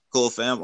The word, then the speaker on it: Cole Pham: Is it Cole Pham? – I like to say Cole Pham, but both are right. – Cole Pham.